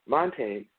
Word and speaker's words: Montaigne: Montage, the French, I can't even pronounce this right. Let's see if we can try to pronounce it together. Montaigne, the French essayist, put it this way.